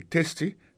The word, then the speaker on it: tasty: Food, F-O-O-D, ça veut dire manger. testi, T-A-S-T-Y, ça veut dire goût, goût dans bouche. T-A-S-T-Y, vous prononcez le testi, ça veut dire goût dans bouche.